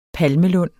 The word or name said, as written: palmelund